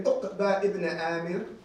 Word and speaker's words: Uqba ibn Amir: Narrated Uqba ibn Amir.